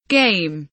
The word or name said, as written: game